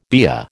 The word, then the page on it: BEA: American English